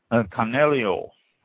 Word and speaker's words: Canaiolo: Next one, Canelio, or Canelio, pronounced both ways, spelled C-A-N-A-I-O-L-O.